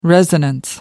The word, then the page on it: resonance: American English